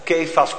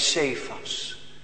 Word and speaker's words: Cephas: En gij zult Cephas genoemd worden. Of Kefas kun je ook uitspreken, Cephas.